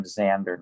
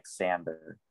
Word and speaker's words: Xander: Ethan, you pronounced the name Xander, not Xander.